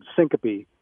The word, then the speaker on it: syncope: And the other word, syncope, is how you pronounce that.